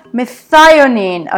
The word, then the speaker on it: methionine: Methionine, okay, methionine amino acid.